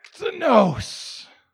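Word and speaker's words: Ektenos (ἐκτενῶς): Ektenos. Got that out there in the lobby? The word is ektenos.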